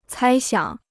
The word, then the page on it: 猜想: cāi xiǎng